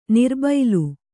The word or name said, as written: ನರಬಲಿ